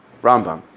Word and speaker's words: Rambam: So the minhag is that when you say Rambam, you put the accent on the right side of the word, Rambam.